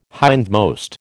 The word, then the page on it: hindmost: American English